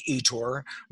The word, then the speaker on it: Ettore: It's just Mike Ettore.